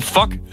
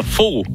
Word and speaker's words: Fogh: Til mit bryllup udtalte præsten mit navn som fuck. – Nej. – På trods af, at jeg hedder Fogh. – Nej. – På samme, altså, hvor man udtaler på samme måde som statsminister Anders Fogh.